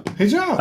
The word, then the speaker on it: hijab: Oh, hijab. – That's how you pronounce it. Hijab.